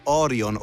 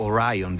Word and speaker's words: Orion: Se ne aggiungono altre, perché ogni tanto ci sono delle missioni, le missioni sono lunghe diversi giorni / mesi, e quindi le capsule Orion o Orion, dipende dalla pronuncia,